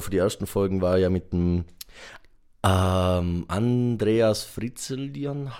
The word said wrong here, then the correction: Frenzelin: Herrgott, wie spricht man das? Frenzelin. Frenzelin, danke. Vom, ja, ja.